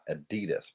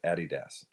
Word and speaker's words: Adidas: And it's supposed to be emphasis on the first syllable, Addy Das, not a D, not Adidas, but Adidas.